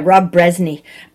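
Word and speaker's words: Rob Brezsny: Rob Bresny, am I saying his name right? – Yeah, Rob Bresny.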